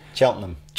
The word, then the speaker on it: Cheltenham: Cheltenham.